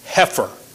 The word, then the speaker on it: heifer: The word there, I believe, is normally pronounced heifer.